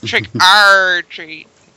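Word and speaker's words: Trick 'r Treat: You know, the one thing that bugs me about Trick or Treat is that you have to overpronounce the R to make sure people know you're talking about the right movie. – Trick R Treat.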